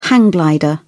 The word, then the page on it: hang glider: British English